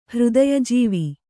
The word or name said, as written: ಹೃದಯಜೀವಿ